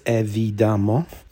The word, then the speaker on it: évidemment: Bit like voila, I think the title's quite easy to say, which is unusual for a French word. Split into four syllables, évidemment.